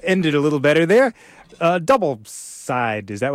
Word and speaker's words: Double: So, from Rhapsody, an artist I can't tell you anything about, because it's not double anymore, or Double, as it's pronounced, it's Double Side.